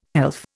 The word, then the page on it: pelf: Indian